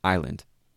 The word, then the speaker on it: island: I-S-L-A-N-D, island. The S never had any reason to be there.